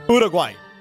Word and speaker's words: Uruguay: Uruguay. – Uruguay. – There you go. It's pretty good. – Uruguay.